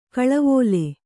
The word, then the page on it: ಖಲೇವಾಲಿ: Kannada